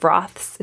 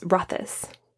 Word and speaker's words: Rothes: Uh, it's not Countess of Roths, it's Rothes think I got that right.